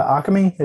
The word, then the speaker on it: Occamy: Unlike James, I, I get where, like, um, we mentioned earlier, the biggest offender is the the Occamy is is that how it's said? Yeah.